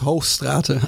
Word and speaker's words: Hoogstraaten: Für Holländisch? Den, für den Deutschen ist es richtig. ähm für mich wäre es Hochstrate.